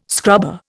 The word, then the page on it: scrubber: Indian